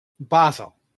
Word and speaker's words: Basil: Basil. Like the herb, except British. – Except Swiss? – Yes. – For an English speaker, like the herb, except British. – Yes. – How I pronounced Basil back in Road Trip Remix. – Precisely. – Not Basil, he was Basil.